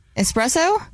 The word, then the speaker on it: espresso: Espresso? – Okay. – There's no X.